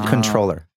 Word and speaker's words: comptroller: It's something that like a comptroller would use. A lot of... – That's pronounced controller. – Is it really? – Yeah. The first pronunciation is the P is silent, but it's been mispronounced so much.